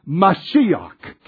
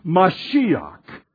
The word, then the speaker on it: Mashiach: In the Hebrew, it's Messiah, or, let me get you the correct pronunciation, Mashiach. Can you say that with me? Mashiach.